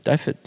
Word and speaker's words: Dafydd: Dafydd. – Dafydd. Is that right? – Yeah. – All right. So we're settled on that pronunciation.